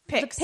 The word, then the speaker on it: Picts: The guys who wore the picks. – P-I-C-T-S.